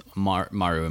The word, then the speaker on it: Maru: So it's Maru, am I saying that right? – Yeah.